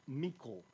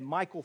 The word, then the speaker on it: Michal: How many of you know, I think her name is actually pronounced Michal, but I've said Michal for so long that I'll probably say that.